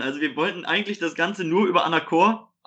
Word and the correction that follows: Anchor: Anachor, wie auch immer es auch ausgesprochen wird. Anchor. – Anchor.